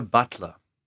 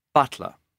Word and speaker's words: Butler: Leo Butler. – Can you spell that, please? – Leo. L-E-O. Butler. B-U-T-L-E-R.